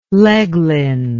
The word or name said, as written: leglen